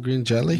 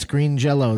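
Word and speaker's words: Green Jellÿ: It's actually pronounced Jello. – Oh, is it? – Yep. – It's not Green Jelly? – Nope, it's Green Jello.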